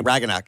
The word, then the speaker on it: Ragnarok: Yeah, Ragnarok.